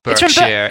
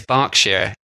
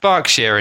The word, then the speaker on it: Berkshire: not Berkshire, it's Berkshire in England. – Why is it spelled B-E-R-K? – Because that's how silly English people spell things. In America, that's pronounced Berkshire, as in like Berkeley University, but it is pronounced Berkshire in England.